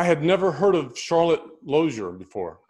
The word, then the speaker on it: Lozier: Am I pronouncing Lozier correctly? – It's actually Lozier.